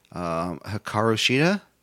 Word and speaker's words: Hikaru Shida: We have singles match, Riho versus Hikaru Shida.